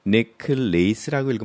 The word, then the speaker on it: necklace: 조심해야 될 것은 이것을 읽을 때 necklace라고 읽으면 안 되는 겁니다. 여기에 문제는 lace가 돼 있지만은 강세가 맨 앞에 있으니까 necklace 해야 되는 거예요. 강세가 있는 거 이외의 단어는 다 어, 이, 짧게 약하게 발음해야 되기 때문에 necklace 이렇게 읽어주시기 바랍니다.